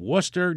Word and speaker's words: Worcester: This one is from Diane in Worcester, New York.